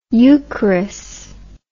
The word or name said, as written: eucharis